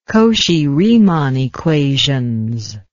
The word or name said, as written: Cauchy-Riemann equations